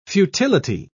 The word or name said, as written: futility